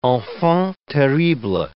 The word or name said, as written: enfants terribles